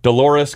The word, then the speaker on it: Dolores: What does Dolores rhyme with? – I always thought that it rhymed with clitoris. Dolores clitoris.